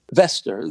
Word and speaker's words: Wester: Wester. The W is pronounced like a V in Sweden.